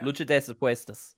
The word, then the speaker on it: Lucha de Apuestas: I'm gonna mispronounce this. Luchas das Apuestas. – Yeah, it's yes, Apuestas. Yeah. Lucha de Apuestas.